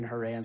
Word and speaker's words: Harran: And for some reason, his father, Terah, stopped in Haran, H-A-R-R-A-N, right? Pronounce it how you will. I'm saying Haran.